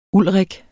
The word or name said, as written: Ulrik